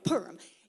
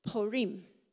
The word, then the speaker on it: Purim: Okay, now, how would you say that word, the one that's highlighted? Purim, right? Purim. It's Purim.